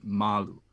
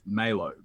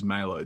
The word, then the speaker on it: Malo: Um, in Ma- in Madagascar, you would actually pronounce it Malu, instead of Malo. But I, I specifically chose Malo because I wanted white people to be able to spell it, and they, they still get it wrong just about every time.